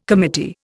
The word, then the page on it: committee: Indian